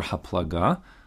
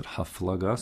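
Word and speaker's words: HaPlaga (הפלגה): Group number two is Dor HaFlaga, or really, technically speaking, it's really Dor HaPlaga, but everyone pronounced it HaFlaga, so we'll stick with that for now.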